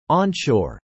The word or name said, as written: onshore